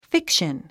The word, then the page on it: fiction: fíkʃən; フィクション